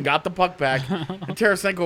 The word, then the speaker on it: Tarasenko: Tarasenko. – Tarasenko.